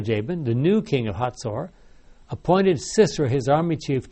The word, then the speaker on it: Hazor: The king of Hazor, H-A-Z-O-R, Hazor, I don't know how you pronounce that, but I think the Hebrews pronounce it Hazor.